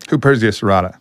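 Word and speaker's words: Huperzia serrata: And okay, so I interrupted you, but this Herpertzia, how do you say it? – Herpertzia serrata.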